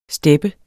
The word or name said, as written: STEPPERNE